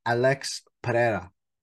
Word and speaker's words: Pereira: Alex Pereira. All right, that's the more traditional way of how you say it.